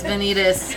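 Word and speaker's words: Vanitas: Or Benita. What's what's his name? Did I say it right? – The Venitas. Okay. Venitas. Whatever. No, it's Venitas.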